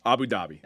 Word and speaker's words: Abu Dhabi: It's Abu Dhabi? – Abu Dhabi.